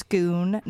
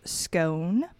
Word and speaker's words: Scone: It's schoon, not scone. – Scoon. Like, what's a scoon? – It's not a scone? It is the place that you go to become king in Scotland.